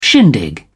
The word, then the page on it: shindig: British English